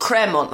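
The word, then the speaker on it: Crémant: I've been told that we're saying this wrong. Cremant. It's not Cremant. It's Cremant. – Cremant, like mammal.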